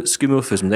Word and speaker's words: skeuomorphism: but is it skeuomorphism? Is that how you say it? – Yes, skeuomorphic.